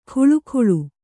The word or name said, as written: ಖುಳುಖುಳು